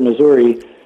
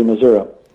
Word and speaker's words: Missouri: Well, it all depends who you talk to, but I was brought up to say that if somebody said Missouri, it showed that they weren't from the state. That okay. – In the state pronunciation was to be Missouri.